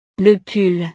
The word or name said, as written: le pull